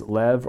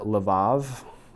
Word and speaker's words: levav: So the Hebrew word is lev or levav. Now, yes, that's a B, but I think in Hebrew it's actually pronounced with a V, so lev or levav.